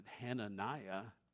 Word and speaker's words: Hananiah: In the Hebrew, you would have pronounced that Hananiah, which means Yahweh is gracious.